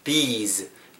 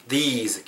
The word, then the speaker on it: these: these, these 길게 발음하고 뒤에 z로 끝나야 복수형으로서 우리가 의미를 전달할 수 있거든요.